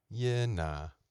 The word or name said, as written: yeah nah